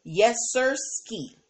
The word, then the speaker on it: Yessirskiii: In particular, apparently 21 Savage came up with this word called Yessirski, spelled Y-E-S-S-I-R-S-K-I-I-I. Okay, I'm going to spell that one more time for you. Y-E-S-S-I-R-S-K-I-I-I.